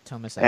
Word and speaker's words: Tomasi: Yes, Tomasi.